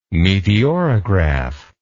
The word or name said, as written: meteorograph